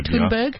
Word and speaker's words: Thunberg: Thunberg? – Thunberg.